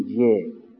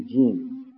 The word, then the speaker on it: جیم: ولی وقتی خواستیم تلفظ بکنیم نمیگیم جه، میگیم جیم. نمیگیم به، میگیم با. با اسم است برای همین این خطی دراز رو میرش نقطه است.